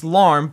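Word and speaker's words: lam: So whenever you pronounce this, it's LARM, but obviously when you're in meditation, it's going to sound something like this. LARM.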